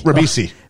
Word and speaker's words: Ribisi: I can't ever remember, say his name right. Giovanni Rigsby? – Giovanni Ribeiro? – Ribisi. – Ribisi.